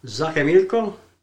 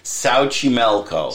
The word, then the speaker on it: Xochimilco: It's Xochimilco? – No, that's not even close. – What was it? – Xochimilco. – That was that far off. – Well, you know. – Xochimilco.